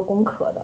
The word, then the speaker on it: 攻壳: 就是你要说到这个的话，那我就一定要给你推荐，就是说学院建制他拍的那个 TV 版的《攻壳机动队》，那个少佐那个身材、那个腰、那个裤子、那个宅，就是说那个性感，你还是可以了解一下的。 – 就顺便安利。 – 我个人是比较倾向于把它念作攻壳的，就是因为它的原名其实是 Ghost in the Shell 嘛。